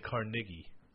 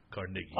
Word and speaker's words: Carnegie: They say Carnegie. – Oh, yeah. They're a little racist. – No. That's just how it's pronounced. Carnegie.